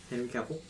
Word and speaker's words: Henry Cavill: Henry Cavill. I said it right this time.